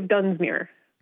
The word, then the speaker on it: Dunsmuir: So it is the city of Dunsmuir.